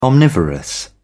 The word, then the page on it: omnivorous: British English